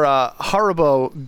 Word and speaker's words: Haribo: Was it Haribo? I'm going to butcher the name. H-A-R-I-B-O. Haribo. – I think it's Haribo.